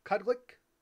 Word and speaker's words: qulliq: Okay, so the name of these lamps, and I know I'm going to butcher this word, is Quillic? Q-U-L-L-I-Q. Quillic? Cudlic? – Yeah, Quillic.